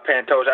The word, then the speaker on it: Pantoja: I want to talk about Askarov and is it Pantoja? Is that how you pronounce it there? That fight. Who do you like there? – Pantoja. I like Pantoja.